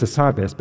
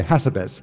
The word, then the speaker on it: Hassabis: It's Demis Hassabis, but they say Hassabis. – Yes. – And I say, no, you have to put emphasis on the right syllable.